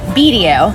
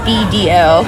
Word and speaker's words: Bedio: Bedio. – Bedio. – So okay. I get so many variations of like Bedio, Bedio. I just think three letters, Bedio.